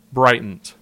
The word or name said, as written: Bryant